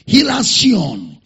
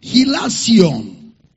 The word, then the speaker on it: hilasion: Paul uses a similar word, hilasion, hilasion, from the word hilasmos. Hilasion is spelled as H-I-L-A. S-I-O-N, hilasion, from the word hilasmos.